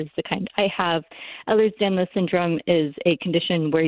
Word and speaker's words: Ehlers-Danlos: He's living with narcolepsy and also has Ehlers-Danlos syndrome.